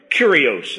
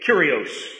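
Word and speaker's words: Kurios (κύριος): Kurios. It sounds like curious, but it's spelled differently with a K. Kurios.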